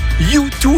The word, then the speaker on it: U2: Et puis en anglais, U2 se prononce U2, autrement dit toi aussi.